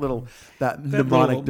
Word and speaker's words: mnemonic: So obviously that acronym doesn't work, or that mnemonic device. – Mnemonic. It's a moronic device. – You actually don't pronounce the M in mnemonic. You actually, that's a silent. – Sure. – That's a silent letter.